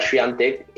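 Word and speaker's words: Świątek: Iga, eh, hay un video en el que ella dice que en inglés le dicen Iga Swiatek y ella prefiere que le diga Swiatek, que es como se pronuncia en polaco pero que ella,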